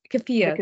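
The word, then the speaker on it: keffiyeh: How impactful do you think it is to see articles such as the Kifa? I'm probably mispronouncing that. Sorry. The Kifa, the Palestinian scarf. Kafia. – The Kafia.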